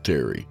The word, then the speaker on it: Terrye: And that was Remember You Must Die by Terry Turpin, pronounced Terry.